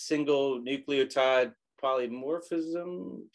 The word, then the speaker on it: single nucleotide polymorphism: which is single nucleotide polymorphism. Did I say that right? – That's right.